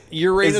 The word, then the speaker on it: Uranus: Uranus, is that, Missouri, Ben? – Is that how it is pronounced, sir? – Yes.